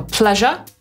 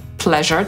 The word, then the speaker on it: pleasure: I Brytyjczyk wymawia to słowo jako pleasure, Amerykanin pleasure. I teraz zobaczcie, gdybyśmy please wymawiali przez s na końcu, to to słowo nie brzmiałoby pleasure, tylko pleasure przez sz. Ale że z jest dźwięczne, to i ż też jest dźwięczne.